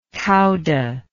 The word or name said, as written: cauda